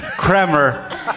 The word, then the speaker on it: Kremer: Who is also not spelled as you would expect it to be. K-R-E-M-E-R. – Right. Pronounced Kramer. I almost messed it up. Pronounced Kramer, spelled Kramer.